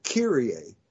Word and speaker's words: Kyrie: Now, the thing, if you focus on Kyrie, we pronounce it Kyrie. We don't say Kyrie eleison at Mass.